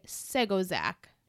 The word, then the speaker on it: Segonzac: Original air date, November 17th of 2008, written by Joshua Safran and directed by Jean de Segonzac. – Ooh. – Segonzac. I think I looked up the pronunciation 3 times. It's French, so I was like, yeah.